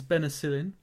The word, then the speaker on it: Benecilin: My name is Benicillin, which is spelled B-E-N-E-C-I-L-I-N.